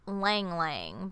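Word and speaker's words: ylang ylang: It's called Lang Lang, but it's spelled Y-L-A-N-G, and then you repeat that.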